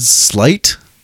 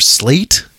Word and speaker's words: Slate: Red Slight? Or Slate? It's spelled like Slay with a T at the end.